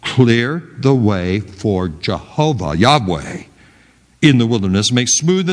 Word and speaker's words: Yahweh: the word Jehovah, or probably better pronounced Yahweh.